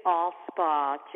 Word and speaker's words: Alspaugh: Okay, Nancy Allspa Jackson.